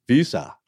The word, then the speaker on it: Weser: And that's spelled W-E-S-E-R. And in German, it'd be close to Visa or Visa.